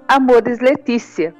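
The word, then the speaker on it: Amoris Laetitia: Esse foi o apelo que fez o Papa Francisco na exortação apostólica pós-sinodal Amores Letícia. Né? É assim mesmo que se pronuncia.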